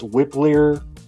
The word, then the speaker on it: Wypler: You got I think it's pronounced Whippler, W-Y-P-L-E-R.